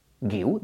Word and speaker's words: Guiu: Si le añades una U, Guiu, ¿de acuerdo? Si la joven promesa del Barça se llama Marc Guiu, es exactamente igual de fácil de pronunciar que en castellano.